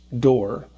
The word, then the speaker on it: dor: The Hebrew word there for generations is, it's pronounced dor,